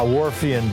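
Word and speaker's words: Whorfian: The Warpian hypothesis. – Nope, not. Thank you for it. How do you spell that? – It's Wharfian hypothesis.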